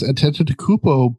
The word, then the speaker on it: Antetokounmpo: So, there is going to be a Giannis Antetokounmpo biopic called The Greek Freak.